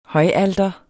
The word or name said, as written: højalter